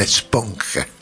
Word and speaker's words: esponja: And I think that I'm kind of like I always say it incorrectly in Spanish. Eponche? Esponge? – Mm-hmm. Esponja.